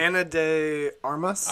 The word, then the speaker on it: Ana de Armas: How do you say her name? – Armas? Ana de Armas? – Armas. Well, I was telling him on Friday's Headliners episode, she has very quickly become, like, my celebrity crush.